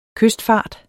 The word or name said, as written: kystfart